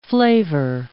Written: flavor

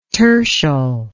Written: tertial

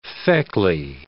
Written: feckly

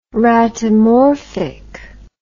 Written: ratomorphic

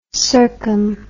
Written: circum

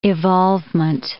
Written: evolvement

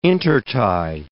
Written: intertie

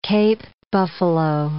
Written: Cape buffalo

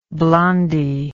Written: blondie

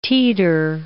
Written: teeter

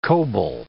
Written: coble

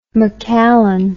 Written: macallan

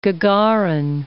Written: Gagarin

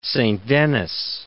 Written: Saint Denis

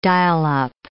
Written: dial-up

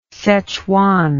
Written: szechwan

